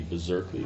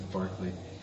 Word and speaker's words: Berkeley: Wouldn't the proper pronunciation be Berserkly? Is that the correct pronunciation? – Actually, one of my class said it's Barkley. – Barkley. Oh, so not Berserkly.